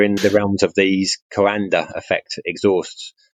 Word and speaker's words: Coanda: Yeah, the Coanda effect.